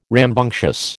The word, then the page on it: rambunctious: American English